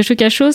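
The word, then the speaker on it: Shuka Sho: Le Shukasho, c'est je ne sais pas si je le prononce bien d'ailleurs. – Très bien. Très bien, très bien parfait.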